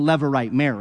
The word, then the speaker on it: levirate: Later in the book of Deuteronomy, Moses, by God, will regulate, give laws regulating a levirate marriage, that's how you say it, but not commanding it.